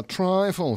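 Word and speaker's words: trifle: Слово трайфл в переводе с английского означает пустяк, мелочь. А пишется как трифле.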